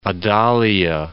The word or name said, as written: Adalia